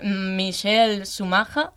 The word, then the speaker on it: Michael Schumacher: Bueno, Elisa, antes de comenzar, nos ha dicho que es Michael Schumacher. – Sí, perfecto. – O sea que está un poco mal pronunciado en España, pero...